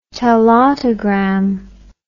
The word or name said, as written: telautogram